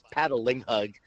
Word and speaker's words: Patangleg: It's Patangleg. It's just like it's spelled.